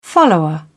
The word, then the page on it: follower: British English